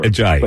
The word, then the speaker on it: Ajayi: Ajayi.